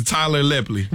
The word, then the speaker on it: Lepley: Isn't it Lepley? I thought it was Tyler Lepley. – Everybody I've ever heard pronounce it as Tyler Lepley.